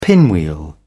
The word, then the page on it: pinwheel: British English